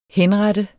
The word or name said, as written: henrette